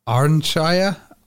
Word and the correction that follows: Ayrshire: Ayrshire? Ayrshire, that's it.